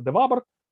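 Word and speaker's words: de Vabres: А, значит, э, я уже упомянул Фрэнсиса Бидла как судья, судьи от э, США и Анри Донадье де Вабр, иногда в советской литературе его называют де Фабром, хотя правильно произносится де Вабр, а, был судьей от э, Франции.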